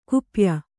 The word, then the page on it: ಗೋಪ್ಯ: Kannada